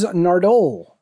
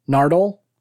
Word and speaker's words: Nardole: Nardole? – Nardole. Nardole. – Nardole. – Mm. – Listen. Nardole, in the next Nardole. Episode, listen to the doctor. He says Nardole. He stresses the second syllable for whatever reason.